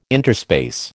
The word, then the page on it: interspace: American English